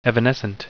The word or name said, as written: evanescent